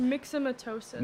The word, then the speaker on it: myxomatosis: Because it's myxomatosis.